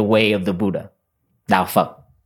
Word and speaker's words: Phật: Yes, it's spelled P-H-A-T. And it's Sino-Vietnamese. Phật. Yeah, it sounds like not a nice word in English language.